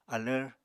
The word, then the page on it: alvr: apatani